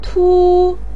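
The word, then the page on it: tu: tone 1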